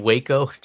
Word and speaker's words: Waco: And I've also been watching this 6-episode miniseries called Wacko. It's about this cult leader in Texas. – Do you mean Waco? – Is that how you pronounce it? – Yes, this is Waco, Texas.